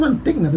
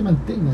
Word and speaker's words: Mantegna: Yo lo pronuncio Mantegna, tú dijiste Mantegna. – ¿No? Es que yo lo escuché en inglés una vez y ahí cambié. Yo también decía John Mantegna. Ah. Es como que la GN hace el sonido de ñ.